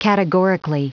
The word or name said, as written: categorically